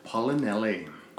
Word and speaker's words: Paolinelli: Paolinelli.